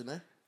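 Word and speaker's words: Jhené: We got Janine Aiko. With who? Say that again. – Janine Aiko. How you say it? – Janae. – Oh, it's Janae.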